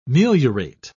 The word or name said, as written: meliorate